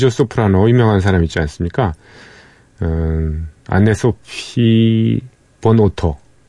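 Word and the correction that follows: von Otter: 예, 안네 소피 폰 오토라고 읽어야 되겠군요.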